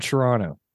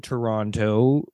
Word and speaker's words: Toronto: This tiny little thing, like them not paying enough attention to how the natives actually pronounce Toronto, not Toronto. We're from Toronto. Second C is it's silent T.